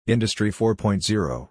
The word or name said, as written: Industry 4.0